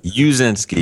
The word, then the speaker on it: Uszynski: How do you say your last name? – Huh? Uzinski. Uzinski is how the grandparents passed it down.